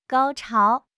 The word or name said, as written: 高潮